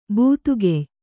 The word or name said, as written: ಬಾದುಗೆಯ್